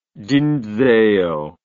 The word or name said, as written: dinzeo